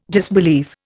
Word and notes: disbelieve: Indian

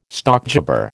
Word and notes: stockjobber: American English

